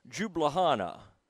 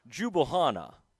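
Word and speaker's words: Ljubljana: It was I'm going to call it Jublahana. It has an L at the beginning, but I feel like the L might be silent. So we're going to call this Jublahana, all right, Slovenia.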